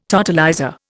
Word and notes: totalizer: Indian

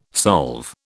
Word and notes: solve: British English

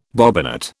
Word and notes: bobbinet: British English

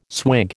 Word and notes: swig: American English

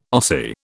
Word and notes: Ossie: British English

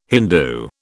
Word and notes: Hindu: British English

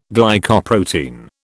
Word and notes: Glycoprotein: British English

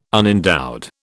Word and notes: unendowed: British English

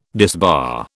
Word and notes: disbar: British English

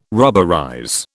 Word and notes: rubberize: British English

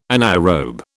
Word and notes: anaerobe: British English

